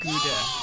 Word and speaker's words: Gouda: It's pronounced Gouda. – It's Gouda, man.